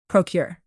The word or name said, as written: procure